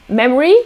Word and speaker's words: memory: Euh, memory, mais en français on prononce vraiment memory.